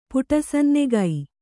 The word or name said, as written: ಪುಟಸನ್ನೆಗೈ